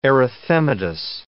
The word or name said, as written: erythematous